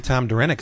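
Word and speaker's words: Derenick: Local boy Tom Durenick is going to be doing some of the backups too. – Is that how you say his name? – Yep.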